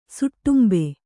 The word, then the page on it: ಸುಟ್ಟುಂಬೆ: suṭṭumbe